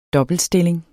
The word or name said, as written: dobbeltstilling